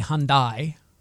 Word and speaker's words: Hyundai: It's actually Hyundai. That's how it's pronounced.